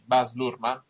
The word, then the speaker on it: Baz Luhrmann: Allora, abbiamo, come diceva il buon Monco, iniziamo con il trailer del grande Gatsby di Baz Luhrmann, si pronuncia così? – Sì.